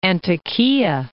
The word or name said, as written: Antakiyah